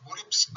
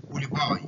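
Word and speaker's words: polypi: لحميات الانف بقى، النيزل بوليبس او نيزل بوليباي، بتنطق بوليبس او بوليباي بس الاصحاوي بوليباي اللي هي جمع بوليب.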